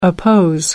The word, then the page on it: oppose: American English